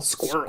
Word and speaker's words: squirrel: So it's S-C-U-R-L, squirrel.